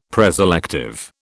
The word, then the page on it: preselective: British English